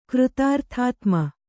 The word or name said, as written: ಕೃತಾರ್ಥಾತ್ಮ